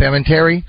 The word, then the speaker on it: Palminteri: Palminteri.